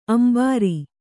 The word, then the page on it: ಅಂಬರಿ: Kannada